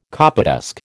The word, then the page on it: copydesk: American English